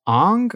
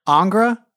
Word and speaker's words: Ingres: Oh, actually, I wrote it down, but I think the pronunciation is Ong or Ingra. Angra. It's like I-N-G-R-E-S.